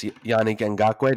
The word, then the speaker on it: Yannick Ngakoue: And then the big one is that in timing that seemed to be on purpose, Yannick Ngakoue, did I say that right? – Mm-hmm.